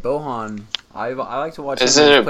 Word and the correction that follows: Bojan: Just isn't it Boyan? – Oh, is it really? – Yeah. – That's how you pronounce that J in there? – Yeah. – Ooh, okay. – Bojan.